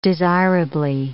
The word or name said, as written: desirably